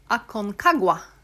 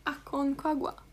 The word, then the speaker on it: Aconcagua: Okej, du får uttala det här. Aconagua. – Det högsta berget i Anderna är då Aconcagua. – Aconcagua.